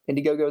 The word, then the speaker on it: Indiegogo: Indiegogo is how you say it. Indiegogo.